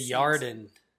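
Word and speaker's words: Yarden (ירדן): Yarden. – Yeah, that's the actual way to pronounce it.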